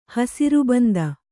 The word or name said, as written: ಹಸಿರುಬಂದ